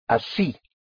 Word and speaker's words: así: Either way, a very useful little word in Spanish is one word for like that or that way, which is así. Así, which is spelled A-S-I, and it has an accent over the I.